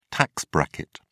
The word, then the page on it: tax bracket: British English